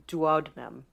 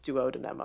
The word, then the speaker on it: duodenum: which severed the intestine at the duodenum. I said that wrong. It's duodenum, I believe, whatever.